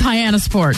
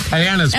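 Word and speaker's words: Hyannis Port: Nobody knows really how to pronounce it. – It's Hyannisport. – Hyannisport.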